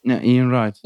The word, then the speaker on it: Ian Wright: Ian Wright. Ian Wright.